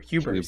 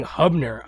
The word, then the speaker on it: Huber: John Hubner? – Is it Huber? – Huber. See that? That's what I'm saying. I keep saying Hubner.